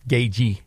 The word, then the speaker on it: Gaethje: Gaethje is how you pronounce it. The TH is silent.